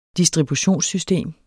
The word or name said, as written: distributionssystem